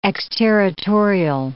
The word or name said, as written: exterritorial